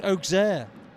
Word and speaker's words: Auxerre: Yes, it's Auxerre. O for Auxerre, although it's spelled with an A, of course.